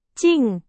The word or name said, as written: 静